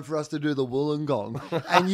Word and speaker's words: Wollongong: and then I'm doing Wollongong.